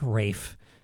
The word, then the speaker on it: Ralph: music that is as English as, well, pronouncing Ralph like Rafe. That's Rafe Vaughn Williams, his English folk song suite, University of Texas Wind Ensemble.